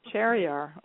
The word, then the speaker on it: Shahryar: Shahryar. – Shahryar.